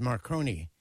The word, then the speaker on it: Marcone: Yes, very interesting, too, because my name is Marconi, but it's spelled with an E at the end and not an I.